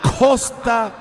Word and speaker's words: Khosta: Y no, ahora tenemos que preocuparnos por el Costa 2. – ¿Costa 2? – Ajá. El Costa, que se escribe con K-H-O-S-T-A, Costa 2.